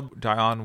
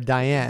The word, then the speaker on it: Dionne: We also saw Dionne Warwick. – Dion. Yes. We got to say her name right first. We did meet a guy that was waiting backstage after the show and kept calling her Diane.